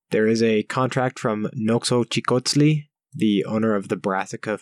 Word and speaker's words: Noxochicotzli: Noxochicotzli. – Noxochicotzli.